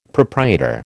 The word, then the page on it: proprietor: American English